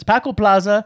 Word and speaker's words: Paco Plaza: It's Paco Plaza.